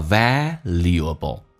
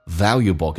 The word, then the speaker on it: valuable: In the second place, 那么第二呢？Most valuable books, valuable, 这个单词在美式发音当中啊比较的独特，因为英式发音叫 valuable，它把 L 往后放，美式发音呢那个 L 提前发叫 valuable 跟 valuable 你要能听懂。